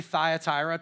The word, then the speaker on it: Thyatira: But we are going to look at the fourth letter written to a city called, we're just going to use the English version. I'm going to save you the Greek pronunciations this morning, okay? But we'll just say Thyatira to make it easy for all of us to say, right?